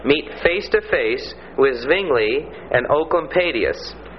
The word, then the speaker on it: Oecolampadius: It's spelled O-E-C-O-L-A. I spelled it wrong. O-E-C-O-L-A-M-P-A-D-I-U-S. And it's pronounced ooh, like O-O in hoot, you know.